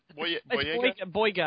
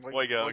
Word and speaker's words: Boyega: Or Boyega, how do you say it? – Boyega, I believe. – Boyega, okay. What's with the whatever.